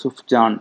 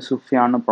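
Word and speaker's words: Sufjan: எஸ்யூஎஃப் JN இருக்கும் so சுஃப்ஜான் அப்படின்னு தான் நமக்கு சொல்ல தோணும் பட் அது வந்து சுஃபியான்னு ப்ரொனவுன்ஸ் பண்ணுமா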